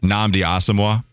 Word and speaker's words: Nnamdi Asomugha: Nnamdi Asomugha.